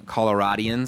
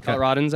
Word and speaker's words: Coloradans: It's obviously a very special weekend for all of us Coloradians. Is that how you say it? – Coloradans, I think.